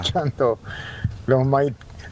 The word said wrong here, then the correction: maitenenses: En realidad es maitenenses, sí.